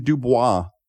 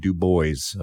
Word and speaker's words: Du Bois: He did not like the French pronunciation. He liked his American pronunciation of Du Bois.